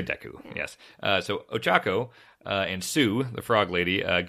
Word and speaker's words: Ochako: How do you say it? Yororako? – Ochako.